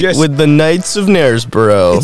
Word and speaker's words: Knaresborough: Knaresborough. – Little known fact, the original Clam Harbor. – The original Clam Harbor, and it starts with a K. And I don't think you pronounce the K.